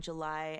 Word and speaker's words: JLY: Yes, I am JLY, and that's spelled J-L-Y because it's actually my initials.